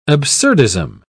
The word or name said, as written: absurdism